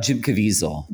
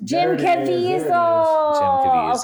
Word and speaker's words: Jim Caviezel: Jim Caviezel. – Jim Caviezel.